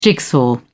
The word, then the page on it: jigsaw: British English